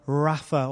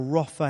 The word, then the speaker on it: Rapha: One of the names for God is about how God heals, Jehovah Rapha, or Rophe, as it's sometimes pronounced.